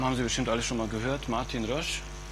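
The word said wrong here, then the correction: Roesch: oder Resch eigentlich wird es genauer ausgesprochen, der Hauptentwickler von Snort.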